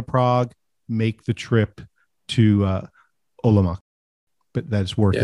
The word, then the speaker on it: Olomouc: Yeah, it's Olomouc.